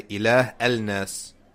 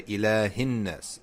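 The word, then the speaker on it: ilahinnas (إله الناس): You don't say ilah al-nas. No, you say ilahinnas. It's just a connector.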